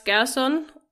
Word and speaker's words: Gerson: Max Gerson is how we always refer to him, but is this how you say it in German? – Probably, yes. I would say Max Gerson.